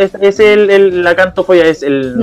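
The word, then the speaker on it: xantofobia: es con X, es con X, pero se pronuncia cantofobia, que es el miedo, ah.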